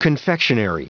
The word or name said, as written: confectionery